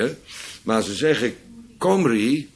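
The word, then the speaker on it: Cymry: En nog verder terug, ze worden genoemd Kimmeriërs of de Kimri, ook uitgesproken als Comri, want zo spreken de Welshmen het uit, hè? Die schrijven Kimri met een lange I, met een Y, en ze zeggen Comri.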